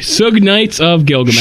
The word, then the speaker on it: Suge: How do you, I don't know how you're supposed to pronounce that. Shug Knight.